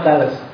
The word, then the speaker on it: Thales: Kalau kamu tidak percaya, bacanya memang Thales.